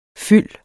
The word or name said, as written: fyld